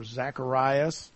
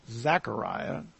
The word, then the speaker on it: Zechariah: This is, of course, we've studied this passage, and it's there is some humor here for Zacharias, but or Zechariah is another way to pronounce this name.